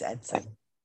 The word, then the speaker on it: Edson: Well, we are planning for an investigation of the USS Edison. And it's Edson. – Edson? Oh, I say it wrong.